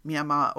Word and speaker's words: Myanmar: spelled M-Y-A-N-M-A-R, but pronounced by all the people who live in the country as Myanmar or Myanmar.